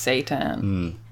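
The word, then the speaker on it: seitan: No, yeah, it's just pronounced Satan.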